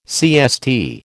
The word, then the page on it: CST: American English